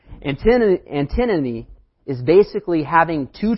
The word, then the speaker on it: antinomy: I've been saying it wrong the whole time. I had to go on Google and make sure I said it right. Antinomy. That's the correct way. I kept saying antinomy.